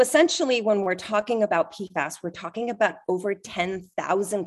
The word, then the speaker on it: PFAS: PFAS. – It's called, you can refer to it as PFAS or PFOS, but PFAS is probably what you're going to hear most people say, PFAS.